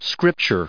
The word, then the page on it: scripture: scrip*ture; American English